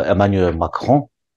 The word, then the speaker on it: Emmanuel Macron: 这个 Emmanuel Macron，这个法文的发音，那他这个马克龙总统，他的夫人可能台湾还有人了解哈，但对法国的政治人物过去是怎么在处理国际关系，可能台湾不了不太了解。